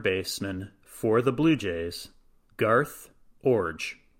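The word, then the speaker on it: Garth Iorg: I have to say, I had no idea how you were going to pronounce that. I intentionally did not research the pronunciation of this player's name. It's spelled I-O-R-G. And of all of the pronunciations, I'm not sure I even imagined that that was going to be the correct one.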